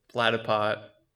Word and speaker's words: Platypot: Did I say that right? – Yeah, it's the Platypot. – Platty Pot or Platty Plot? – Platypot. – Platty Pot. – Platypot. – Platypot? – Yeah, it's like Platypot.